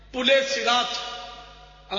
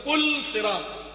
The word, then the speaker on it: پل صراط: خیانت بولتے ہیں ہمارے, ہے خیانت, پلے صراط عام طور پہ بولتے ہیں, حسر تلفظ ہے پل صراط, لام پر جذب ہے پل صراط.